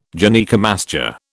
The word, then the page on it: Gynaecomastia: British English